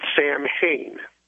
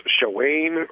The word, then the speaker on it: Samhain: And last night, they were talking about an ancient festival named, I believe Dale pronounced it, Samhain. – Mm-hmm. – And a caller called up and corrected him and said it was pronounced Shawain or something like that.